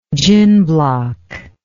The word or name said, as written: gin block